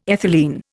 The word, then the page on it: ethylene: Indian